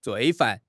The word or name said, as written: 罪犯